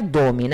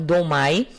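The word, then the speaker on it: Domme: A pronúncia varia geralmente, é, é pronunciado dom mas às vezes pode ser pronunciado domai, para enfatizar a diferença entre dom feminino e o masculino, que é inante.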